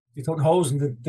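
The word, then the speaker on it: Die Toten Hosen: I imagine you probably toured a lot with Die Totenhausen. Am I saying that right? – Yeah, Die Totenhausen, the Dead Trousers.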